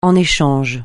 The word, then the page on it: en échange: francais